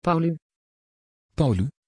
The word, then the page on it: paulu: Dutch